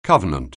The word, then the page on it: covenant: anglais